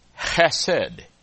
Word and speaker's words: chesed: But the word is chesed. H-E-S-E-D. Chesed is how you say it. To be able to say it correctly, you have to have bronchitis. H-E-S-E-D.